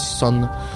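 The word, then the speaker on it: Sunn O))): Ça s'écrit Sun O, mais on dit Sun.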